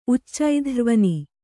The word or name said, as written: ಉಚ್ಚೈಧ್ರ್ವನಿ